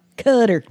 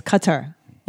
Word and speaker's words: Qatar: I'm pretty sure it's not pronounced Cutter. – Well, the journalist kept saying Cutter. – Qatar. I think it's Qatar. Like that, like Qatar, but not Cutter.